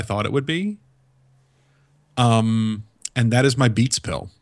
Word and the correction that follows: Beats Pill: I think you put the emphasis on the wrong syllable there. Did you mean your Beats pill? – My Beats pill, yes.